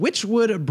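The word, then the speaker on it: Wychwood: The beer today is from Witchwood Brewery, and that's spelled W-Y-C-H, Witchwood Brewery, and it's called the Hobgoblin.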